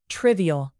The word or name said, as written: trivial